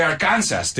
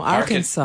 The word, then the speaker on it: Arkansas: And we don't say Arkansas, do we? – No, Arkansas. – Arkansas. And you got to say it like this.